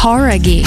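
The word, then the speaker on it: Jauregui: Horaghi. Who é? – Horaghi. – Horaghi. – Horaghi. Ah, então a gente tava falando errado, cara.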